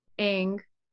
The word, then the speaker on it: Aang: That was on fire at the end, but then Aang, I'm saying it right. – Yes. Yes, you are.